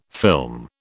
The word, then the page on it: film: British English